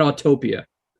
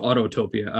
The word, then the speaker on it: Autopia: I think it's like your transportation rides, not Autopia. And also not pronounced Autotopia. I don't know why people do that either.